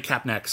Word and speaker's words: Kapnek: Like, I would really love to like interview Emily Kapner. Or Emily Kapnek, sorry. – Kapnek.